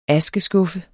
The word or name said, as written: askeskuffe